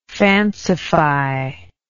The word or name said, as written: fancification